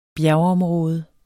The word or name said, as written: bjergområde